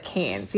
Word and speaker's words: Cairns: I wrote Cannes to pronounce it, so I think that's right. Yeah, Cannes. It's spelled, like, okay. Cairns, but Cannes.